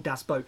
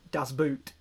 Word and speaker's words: Boot: And yes, I pronounced that correctly, because it is actually, boot is pronounced boat, so it is actually called Das Boot.